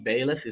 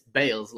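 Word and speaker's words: Bayles: Uh, uh, my cousin Mahesh called me up yesterday and he's like, man, uh, Biwali Bayless It's not Bayless, it's Bales, like cricket ah, Bales.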